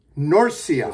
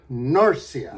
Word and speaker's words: Norcia: Did I say it right? Norcia. – It's like horse, but Norse. – Norcia.